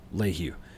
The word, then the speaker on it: Lehew: It's I think my favorite, because I mean, it's for those of you who don't know, it's spelled L-E-H-E-W and it's pronounced Lehu.